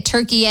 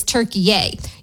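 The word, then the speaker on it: Türkiye: But we'll see what happens tonight in their game against, I guess now we say it, Turkey A. I always say Turkey, but the official pronunciation of the country that the U.S. is playing tonight is Turkey A.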